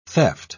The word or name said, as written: theft